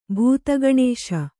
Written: ಭೂತಗಣೇಶ

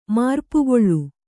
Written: ಮರಿಪುಗೊಳ್

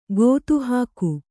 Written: ಗೊತ್ತುಹಾಕು

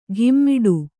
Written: ಘಿಮ್ಮಿಡು

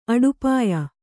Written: ಅಡುಪಾಯ

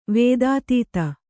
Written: ವೇದಾತೀತ

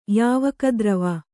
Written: ಯಾವಕದ್ರವ

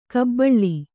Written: ಗಬ್ಬಲು

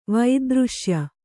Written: ವೈದೃಶ್ಯ

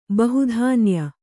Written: ಬಹುಧಾನ್ಯ

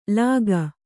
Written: ಲಾಗ

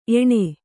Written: ಏಣ್

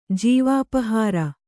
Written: ಜೀವಾಪಹಾರ

